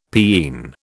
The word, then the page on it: peen: British English